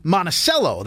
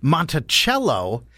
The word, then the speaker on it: Monticello: They say Monticello. That's how you say it. However, our new listeners in Charlottesville, Virginia, will be cringing because they know it's Monticello.